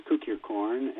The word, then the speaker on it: Kukierkorn: His name is Polish. In Polish, it means cukier, means sugar. But it's pronounced Kukierkorn, and he's a native of Brazil, but all of his grandparents came from Poland.